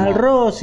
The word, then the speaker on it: Malraux: Malraux, sí.